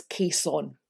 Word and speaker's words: caisson: I always tend to say caisson, but it is caisson. – It's caisson, yes. It's C-A-I-S-S-O-N.